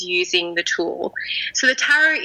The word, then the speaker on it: tarot: I have heard it tarot, I've heard it tarot, I've heard tarot, like carrot. So I say tarot,